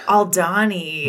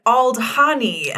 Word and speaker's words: Aldhani: Aldani. Mm-hmm. I looked at it and was like, Aldhani. And Aldhani. Sam was like, absolutely not. – Yeah, that's how a tourist would pronounce it.